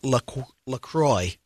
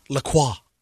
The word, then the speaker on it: LaCroix: So officially, it is LaCroix. Rhymes with enjoy, not LaCroix.